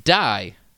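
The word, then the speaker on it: Dai (大): The kanji for the O in Osaka can be pronounced Dai, meaning big.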